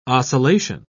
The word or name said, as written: oscillation